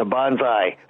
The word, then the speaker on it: bonsai: The bonsai. – Or, bonsai. – Bonsai. – Bonsai. – It's correct.